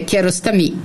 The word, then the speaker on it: Kiarostami: Che si dovrebbe dire Kiarostami, perché in farsi la pronuncia, l'ultima parola è sempre accentata alla fine.